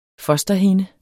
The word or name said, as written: fosterhinde